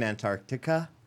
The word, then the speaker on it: Antarctica: So it's -22 in Antarctica. and it's negative 18. – You better just pronounce every letter in that fucking word. Antarctica.